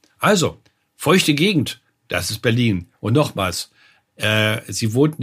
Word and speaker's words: Berlin: Und Berlin, wenn du das achtest, betonen wir hinten.